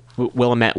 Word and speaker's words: Willamette: but one I saw is in Willamette Week, or Willamette, I believe it's pronounced, and that's up in Oregon.